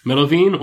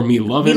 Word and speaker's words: Melovin: Melovin or Milovin. – Milovin. – Interestingly, we heard both pronunciations from official parties. That is, the American commentators definitely called him Milovin. Whereas the hosts definitely used Melovin, but whatever.